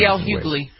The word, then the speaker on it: Hughley: The comedian D.L. Hughley, is that how you pronounce his name? I think I've heard it pronounced another way. – D.L. Hughley. – Hughley, all right, has had he didn't have the same symptoms everybody else did.